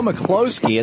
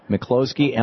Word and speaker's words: Mikloski: Today, our guest is Joe McCloskey. It's spelled M-I-K-L-O-S-K-I. And that's important because that's his website. It's Joe, J-O-E, McCloskey, M-I-K-L-O-S-I.